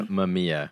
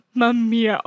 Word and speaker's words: Mamiya: And there's one camera, I'm probably gonna butcher the name. My Maema. – My oh yeah, Mamiya. – Ma, Mamiya. Oh my gosh.